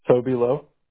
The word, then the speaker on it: Lowe: Toby Lowe. – How do you spell that? – T-O-B-Y-L-O-W-E. – G-O-B-Y, you said?